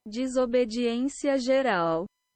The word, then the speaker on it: Desobediência Geral: So, Desobediência Geral. – There you go. That is the name pronounced in Portuguese.